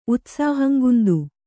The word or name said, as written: ಉತ್ಸಾಹಂಗುಂದು